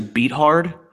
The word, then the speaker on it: Beathard: So we were facing a rookie, as we mentioned in his first start, CJ Beathard, is I learned how they actually I think you mispronounced that. It's actually Beathard.